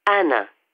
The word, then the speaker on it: Ana: S-T-A-P-L-E-S. – And what's your first name? – Anna. A-N-A.